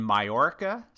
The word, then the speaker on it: Mallorca: In Mallorca, Spain. I think I'm saying that right, right? – Yes, you are.